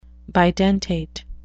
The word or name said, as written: bidentate